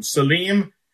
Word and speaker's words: Salim: Salim, Aenon, right next to it, along the Jordan River, just south of the Sea of Galilee.